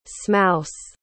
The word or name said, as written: smouse